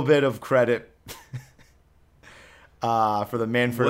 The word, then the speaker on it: Manfred: It wasn't anything specific, but she pronounced the name Manfred and was like, it was like it's spelled Fred. I've seen it that it's supposed to be pronounced Fred, but it's like a soft Fred. It's like you can jumble the letters together.